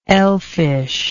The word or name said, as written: elfishness